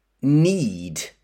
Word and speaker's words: knead: Next, a noun, loaf, L-O-A-F, loaf. And then another noun, dough, D-O-U-G-H, dough. And then a verb, knead. But listen to the spelling, K-N-E-A-D. So we have a silent K, knead. And then another verb, prove, P-R-O-V-E.